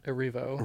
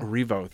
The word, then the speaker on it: Erivo: But I'm so excited to see it because I'm going to mispronounce her last name, but Cynthia Erivo. – Erivo, thank you.